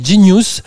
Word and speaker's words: Jeen-yuhs: Voilà, il y a un truc qui est génial qui est sorti qui s'appelle Genius. Alors c'est un peu bizarre, ça dit Genius, mais ça s'écrit J-E-E-N-Y-U-H-S, qui est le nom un peu écrit façon euh, banlieue américaine pour dire Genius.